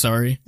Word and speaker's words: Surrey: Is it S-U-R-R something E-Y? What? – Yeah, yeah. – Okay, it's sorry. It's just pronounced sorry.